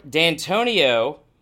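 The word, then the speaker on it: Dantonio: Mark D'Antonio. – You said it right.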